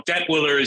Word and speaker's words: Detwiller: I've heard Detwiller and Detwiller, and you know, Detwiller is usually the pronunciation.